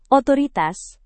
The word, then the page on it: otoritas: Indonesian